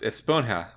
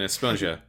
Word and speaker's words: Esponja: which is that it's supposed to be pronounced Esponja with a HJ rather than Esponja.